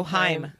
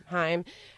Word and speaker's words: Haim: Oh, Haim. – Haim.